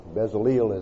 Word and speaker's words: Bezaleel: And he always wrote E-L as it were A-I-L. That E-L should be Ale. E-L should be Ale. And then if we look at the phonetic spelling of this man's name, we see that it is Bezaleel. Bezaleel is Bezaleel.